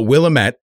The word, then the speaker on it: Willamette: But Willamette is pronounced Will-Amet. Amet. Emphasis on the second syllable.